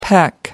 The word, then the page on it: peck: American English